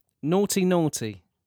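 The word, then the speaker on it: Nortei Nortey: Naughty Naughty. That's his first name is Norti, and his last name is Norty, and it's pronounced naughty naughty.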